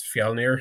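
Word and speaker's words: Fjölnir: I think he's Fjolnir. Is that how you pronounce that? From the Northman? – Yeah.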